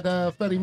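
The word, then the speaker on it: Fete: Providence, Rhode Island at Fetty Music Hall. – Look at that picture. – The second E is silent. – Is it? – It's just Fet. – It's just Fet? – It's Fet Music Hall.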